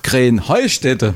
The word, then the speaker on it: Krenheinstetten: Ja, ihr saget immer Krenheinstetten. Und dabei heißt es doch Krähenheustätte, oder wie? – Krähenheustätte. – Ja, genau, und das die Betonung auf dem Heu.